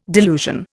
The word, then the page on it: delusion: Indian